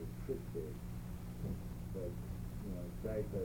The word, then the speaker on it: Zicha: You know, it's spelled out Zyza. Z-I-C-H-A. Zyza.